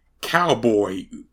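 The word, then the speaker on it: Calboy: Cowboy, and yes, that's, and by not, I mean it's spelled C-A-L boy, like cow as in Calvin, which is actually his real name.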